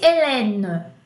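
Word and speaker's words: Hélène: Michelle, M-I-C-H-E, accent grave, L-E. E se fosse Helene? Helene. Três E's e cada E pronuncia de uma forma diferente.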